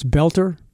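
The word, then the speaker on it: Boelter: Is it Belter? I've heard like three different definitions, and then I go to pronounce it. It was Belter. So it's Belter? Okay, Belter. So Vance Belter seems to be a Republican.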